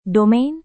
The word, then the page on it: domain: Indonesian